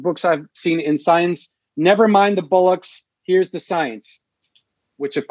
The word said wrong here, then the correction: bollocks: And you mispronounced the word, it's bollocks.